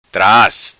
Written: Tras

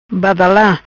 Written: Badala

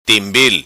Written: Timbil

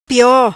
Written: Pio

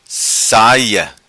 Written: Saia

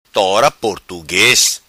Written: Tora Portugues

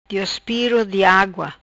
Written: Diospiro di agua

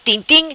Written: Tin tim

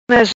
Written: Maz

